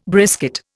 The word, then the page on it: brisket: Indian